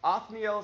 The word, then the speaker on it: Othniel: Othniel is how you say his name.